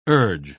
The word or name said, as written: urge